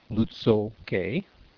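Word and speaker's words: Łutsël K'é: Inbound to Lutso Cay, pronounced Lutso Cay, I report my position on the airport's CARS frequency.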